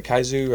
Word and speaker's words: kaiju: Is that how you say it? Kaiju? – Kaiju.